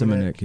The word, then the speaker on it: Shimonek: There's a guy today that I trust on the radio that says the H is silent. He says it's Simonek. He said it's Simonek.